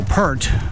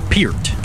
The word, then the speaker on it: Peart: And a man that many of us call Neil Peart, but actually pronounced Neil Peart.